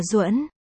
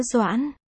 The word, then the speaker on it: Doãn: Nó có hai cách đọc. Trên Wikidict chọn cách đọc là Duẫn. Nhưng mình thấy nó khó đọc quá. Nên mình chọn cách kia là chữ Doãn.